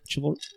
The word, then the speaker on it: chivalrous: Is that the word? Chivalrous. – Soft C. – Chivalrous. – Chivalrous. – Yeah. Chivalry, yeah.